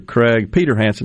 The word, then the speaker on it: Craig Peterhansen: Joining us now in the studio, Mr. Craig Peterhansen. Did I say that right, Craig? – That's correct, I did.